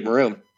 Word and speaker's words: Marune: So I named it Maroon.